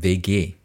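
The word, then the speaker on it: WG: 叫做 vege。vege 英文是 W G，只是用德文发音就是 vege。好，所以之后如果我们讲 vege，你们就知道是这种大家一起 share 的公寓这样。好，那而且那整个 vege 呢，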